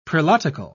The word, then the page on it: prelatical: British English